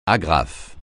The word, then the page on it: agrafe: French